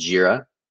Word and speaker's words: Jira: Also on the technical side of it, a great system that we use as a developing team is a system called Jira.